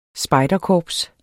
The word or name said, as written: spejderkorps